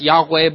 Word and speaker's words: Yahweh: It can be pronounced Yahweh, but it's like the Hebrew for I am.